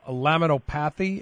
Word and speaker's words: laminopathy: And I thought progeria was considered something called the laminopathy.